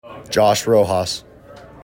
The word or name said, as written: Josh Rojas